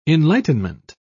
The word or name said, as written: enlightenment